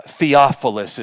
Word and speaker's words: Theophilus: And these letters were written to a gentleman by the name of Theophilus. Theophilus is the pronunciation. First time I read it, I think I may have said like Snuffleupagus or something.